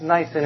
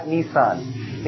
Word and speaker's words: Nisan: By the way, it's pronounced Nisan in Hebrew, not Nissan. Nissan is a Japanese car maker. Okay? Not Nissan, Nisan.